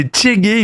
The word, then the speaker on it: Tshegue: C'est le nom du projet, c'est Tchégué, il me semble que ça se prononce comme ça, T-S-H-E-G-U-E.